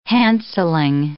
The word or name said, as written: handselling